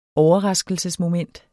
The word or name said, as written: overraskelsesmoment